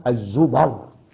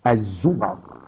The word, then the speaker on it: الزمر: الآية في مستهل سورة الزمر، وهكذا تنطق يا أحباب. يعني في بعض الطلاب ابتدائي اعدادي ثانوي جامعة حتى مشايخ وينطقونها بضم الميم. لا يا حضرات، الزمر.